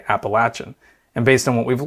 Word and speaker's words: Appalachian: Yeah, so as you may know, there's a couple of ways to pronounce the name of our region. You can say Appalachian, you can say Appalachian, and where we grew up in Western Maryland, more people tend to say Appalachian.